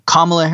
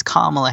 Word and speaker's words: Kamala: The bare minimum you can do is say her name correctly." And then he goes, Kamala Harris, whatever. And he's like, "No, not whatever. It's Kamala Harris."